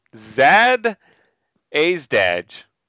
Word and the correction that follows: Jadzia Dax: Jadzia Dax. – Perfect. – Mm. – Jadzia Dax.